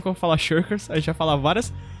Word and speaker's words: Shirkers: Mas, Mas, porque Shirkers é um nome muito complicado, Shirkers.